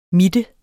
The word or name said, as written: mitte